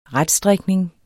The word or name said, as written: retstrikning